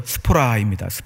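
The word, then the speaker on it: 스포라입니다 (σπορά): 씨앗이라는 단어가 헬라어 발음으로 스포라입니다. 스포라.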